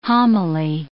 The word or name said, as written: homily